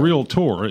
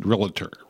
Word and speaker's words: realtor: Realtor. It's not realtor. People put three syllables in realtor, and it's realtor.